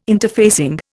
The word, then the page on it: interfacing: Indian